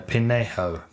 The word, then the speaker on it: Pinheiro: Seems like Pinheiro. That's not what V said, but no, Pinheiro. – Pinheiro.